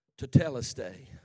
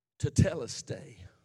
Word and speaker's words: Tetelestai: Tetelestai. Tetelestai is the Greek for it is finished.